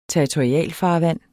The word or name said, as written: territorialfarvand